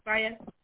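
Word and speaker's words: Faiyaz: Ooh, do you pronounce it Fayez? – Yeah. – Fayez.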